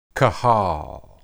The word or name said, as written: qahal